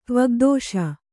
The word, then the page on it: ತ್ವಗ್ದೋಷ: Kannada